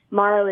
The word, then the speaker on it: Marrow: And Morrow is spelled M-A-R-R-O-W.